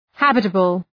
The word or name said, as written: habitable